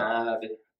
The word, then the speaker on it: Ivey: Governor Ivey. – You know, if Governor Ivey says,